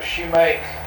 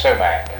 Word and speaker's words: sumac: Have you ever heard of a shoemake? – Oh yeah, sumac. Yeah, sumac. – Uh-huh. – Yeah, it's pronounced sumac.